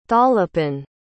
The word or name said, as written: tholepin